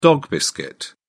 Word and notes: dog-biscuit: British English

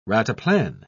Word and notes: rataplan: British English

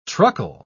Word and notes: truckle: British English

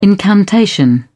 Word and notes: incantation: British English

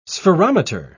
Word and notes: spherometer: British English

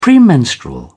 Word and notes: premenstrual: British English

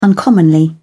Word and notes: uncommonly: British English

